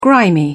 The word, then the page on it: grimy: British English